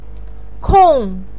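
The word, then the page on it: kong: tone 4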